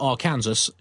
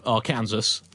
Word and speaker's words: Arkansas: Dear John and Andy, as a native Arkansan, I take umbrage at Andy's suggestion that Arkansas should be pronounced the rhyme with Kansas.